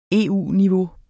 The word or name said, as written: EU-niveau